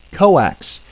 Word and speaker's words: coax: Coax, usually mispronounced as two syllables,